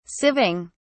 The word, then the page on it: sieving: British English